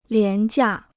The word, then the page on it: 廉价: lián jià